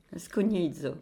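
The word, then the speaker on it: scugnizzo: Je pense que quelques professeurs d'italien de notre connaissance te reprendraient sur la... – Pourtant, j'ai écouté la prononciation, mais bon. – Scognizzo. Voilà.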